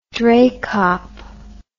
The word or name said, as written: dreykop